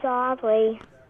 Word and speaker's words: Aubrey: My name's Aubrey. – Spell that, please. – A-U-B-R-E-Y. – A-U-B-R-E-Y. – Uh-huh. – I think it's A-U-B-R-I-E.